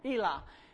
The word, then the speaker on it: Elah: The Philistines were starting a battle with the Israelites, and they were in the Valley of Elah. And did I say that right? Yeah, Elah.